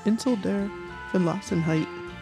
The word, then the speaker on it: Insel der Verlassenheit: Yeah, Insel der Verlassenheit. That is how you say it.